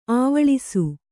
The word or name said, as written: ಅವ್ವಳಿಸು